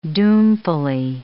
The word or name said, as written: doomfully